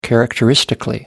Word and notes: characteristically: British English

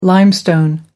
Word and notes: limestone: American English

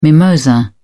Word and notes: mimosa: British English

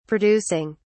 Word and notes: producing: British English

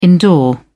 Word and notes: indoor: British English

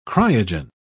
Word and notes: cryogen: British English

